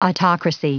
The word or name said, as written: autocracy